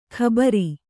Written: ಘಾಬರಿ

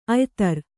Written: ಅಯಿದಾರೆ

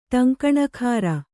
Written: ಟಂಕಣಕಾರ

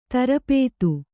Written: ಧಾರಾಪಾತ

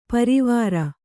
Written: ಪರವರಿ

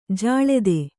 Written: ಜಲಧಿ

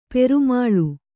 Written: ಪ್ರೇಮಲೆ

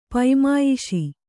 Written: ಪೈಮಾಯಿಷಿ

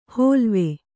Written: ಹೋಲ್ವೆ

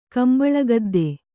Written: ಕಂಬಳಗದ್ದೆ

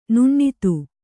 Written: ನುಣ್ಣಿತು

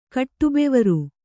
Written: ಕಟ್ಟುಬೆವರು

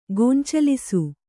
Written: ಗೊಂಚಲಿಸು

